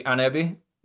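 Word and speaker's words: Ahnenerbe: So the closest thing I could get was a German pronouncing it, and they just like skip one of the ends, so they called it the anebi, which I was like, okay, fair enough.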